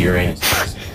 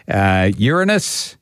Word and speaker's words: Uranus: There's actually three correct ways of, and you're not wrong in whether which way you actually pronounce it. Uranus, Uranus, or Uranus. The three of them are correct. So there you go. If somebody says to you, oh, you said that wrong, no, you haven't. You've said it the right way, because there is three ways of saying the seventh planet from the sun.